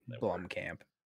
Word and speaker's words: Blomkamp: Blomkamp.